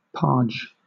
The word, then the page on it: parge: British English